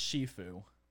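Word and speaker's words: Sifu: I think that's pronounced Shifu. – All right. It's S-I-F-U.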